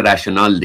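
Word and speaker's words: rational: که البته تلفظ انگلیسیش میشه راشنال دیگه، منطقی احتمالا شاید بتونیم، یا اگر ترجمه دیگری شما در موردش دارید حتما به ما بگید.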